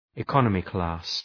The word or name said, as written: economy-class